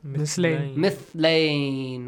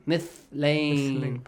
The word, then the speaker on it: مثلين: مثلين. – Not مثلين. مثلين. Pronounce it correctly, Hassan.